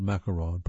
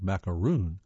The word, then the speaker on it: macaron: The smooth sandwich cookie called macaron, pronounced macaron, as opposed to the bumpier coconut-flavored macaroon,